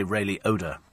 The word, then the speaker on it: Ray Liotta: And Ray Liotta, or as the Americans say, Ray Liotta.